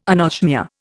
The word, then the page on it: anosmia: Indian